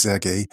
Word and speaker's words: Serhii: Zunächst einmal der Ukrainer, Serhii, wie du sagst, es spricht sich Sergei. Im Ukrainischen das H wird wie das G gesprochen.